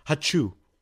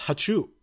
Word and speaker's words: 2SU: 2SU is how it is, but it's pronounced Hachu. So it's, like, a sneeze.